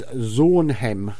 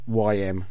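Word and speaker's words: Zornheym: And apologies to them if I pronounce their name wrong. It could be Zornheim. It's Zornheim. So Zornheim, Zornhem. Anyway, it was a great song.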